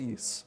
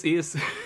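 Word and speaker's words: Ys: Yis franchise. I think that's how you pronounce it. Is it Yis? It's just spelled Y-S, but I've only ever heard it pronounced Yis. – I think it is Yis, but I'm not sure.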